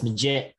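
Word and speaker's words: Magette: Is it Maggette or Maggette? Josh, how do you say it? – It's Maggette. So wrong twice.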